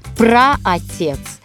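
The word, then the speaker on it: праотец: Пра-отец, пра-отцы. Ударение именно такое. На приставке.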